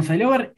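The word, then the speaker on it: Psylover: Eh, Diego, recuerden los puntos de contacto con Sailover, por favor los puntos de contacto con Silover, eh, Instagram es eh, Silover Rock, ¿sí? Eh, se escribe P-S-Y Lover, como si fuera Love.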